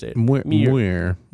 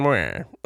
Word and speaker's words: Muir: I think that's right. Muir. It's like if you're really thinking about something. – Muir. – Muir. – John Muir.